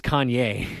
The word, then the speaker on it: Kanye: Conway West. – Oh, is that how you pronounce it? No. It's Kanye. It's Kanye.